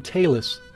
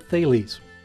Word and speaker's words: Thales: Some of the people we talked to pronounced his name Talus, but the Greeks actually pronounced it Thales.